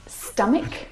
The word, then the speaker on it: stomach: Words like stomach and ache. The CH in all of these words is pronounced like a K sound.